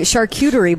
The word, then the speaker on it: charcuterie: She made my charcuterie board for me. – Hey. – Is that how you say it? – Charcuterie? Yeah.